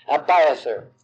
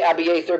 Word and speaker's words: Abiathar: And men, women, and children, nobody escaped from Nob except a few, one of them being Ahimelech's son named Abiathar. We say Abiathar, but phonetically in the Hebrew it's Abiathar, so we'll use that.